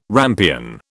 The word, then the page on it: rampion: British English